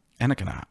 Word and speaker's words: Anakinar: Ooh, Anakar. – Anakinar. You're missing a syllable. Anakinar.